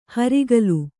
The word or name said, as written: ಹರಿಗೋಲು